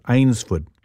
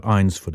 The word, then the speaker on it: Eynsford: It was called Eynsford, not Eynsford. It's one of those English words that doesn't look like how it's said.